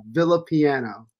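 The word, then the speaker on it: Villapiano: For everybody out there, it's pronounced Villa Piano. So yeah, see, it's just Villa Piano. – Villa, but for some, you know, Villa, like the Roman villas, the small houses in Italy, and piano, like the musical instrument.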